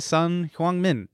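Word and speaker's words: Son Heung-min: The South Korean guy who's playing today, Sun Heung-min. – That was a great pronunciation.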